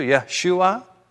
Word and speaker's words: Yeshua: And what's so remarkable that in the name of Jesus, packed into that name, two syllables in the English, three syllables in the Hebrew, Yeshua,